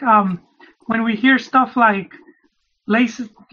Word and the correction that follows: Leicester: I don't know how to pronounce that. Laces Leicester. – Leicester. Just, say, just um, say Leicester. Leicester. – Just say Leicester.